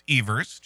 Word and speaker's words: Evers: We have Tony Evers, the school superintendent, and people, it is pronounced Evers, just so you know, saying that he may run.